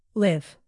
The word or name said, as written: live